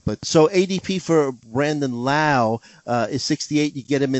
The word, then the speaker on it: Lowe: Brandon, I called him Brandon Lowe. He's actually Brandon Lau, right? Rhymes with now.